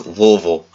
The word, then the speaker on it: Louisville: I'm like, no, it's Louisville. L-U-H-V-I-L-L-E.